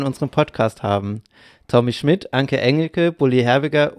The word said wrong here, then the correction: Herbig: Aber auch Bulli Herbig. – Okay. Bulli Herbig, sorry, ich habe den Namen falsch gesagt.